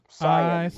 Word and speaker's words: Saiyan: What's the proper pronunciation? – Saiyan. In the Japanese, it's Saiyajin. So, Saiyan would be the more correct.